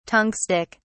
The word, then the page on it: tungstic: British English